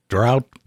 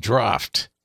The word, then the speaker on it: draught: "It's pronounced draft," Schultz replied. "It's the same as draft, you know, like draft beer."